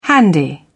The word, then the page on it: handy: British English